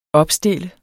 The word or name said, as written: opstille